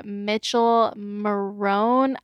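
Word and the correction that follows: Michele Morrone: Is it Mitchell or Michelle? – I just assumed Mitchell, but I don't know. – Michelle Marone. Marone. – Yeah, that sounds accurate.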